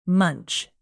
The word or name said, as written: munch